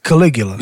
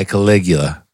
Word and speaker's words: Caligula: Just say it right. – Caligula. – No. Say it right. – I said it. – No, but say it again. – I said it. – The last G is harder. You got to say that guh.